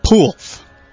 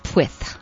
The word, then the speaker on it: Pwyth: This is from Poolth, spelled P-W-Y-T-H, pronounced Poolth here. – Which I don't understand, but okay. – You're not Welsh. – More on that in a second. – I would call that Pwith.